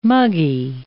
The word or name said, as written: muggy